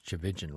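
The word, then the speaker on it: Tchividjian: I know it's pronounced Chavijan, right? Because that's on his website, how to pronounce the name.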